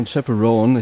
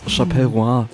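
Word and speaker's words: Chappell Roan: Nämlich, oh mein Gott, dieses Mal kann ich es richtig aussprechen. Pink Pony Club von Chaperone. Ich habe es Chaperoin das letzte Mal ausgesprochen, wie ein Vollidiot.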